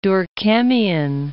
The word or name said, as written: Durkheimian